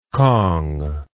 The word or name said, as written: cong